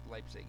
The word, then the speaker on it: Leipzig: Is that how you pronounce it? Leipzig? – Leipzig, yeah.